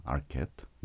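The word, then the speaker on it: Arquette: 어, 주연을 맡은 음, 로잔나 아큐트 이름이 아큐트네요 예, 프랑스 쪽 발음이죠. 그러니까 로자나 아르케트.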